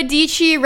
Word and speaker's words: Dietschy: All right, welcome to the Sarah Dietschy Rhymes with Peachy show.